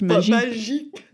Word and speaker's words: magic: Spelled like magic, but pronounced magique.